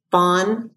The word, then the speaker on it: Vaughan: I am on social and Twitter at Meredith Vaughan. My last name is spelled V-A-U-G-H-A-N.